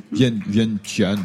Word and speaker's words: Vientiane: Vientiane, je ne sais pas comment on prononce. – Vientiane. – Vientiane. – Oui, il y a un i. Hein, c'est Vientiane. – Vientiane. Oui, je crois que c'est comme ça qu'on prononce. V-I-E-N-T-I-A-N-E.